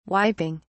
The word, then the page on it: wiping: British English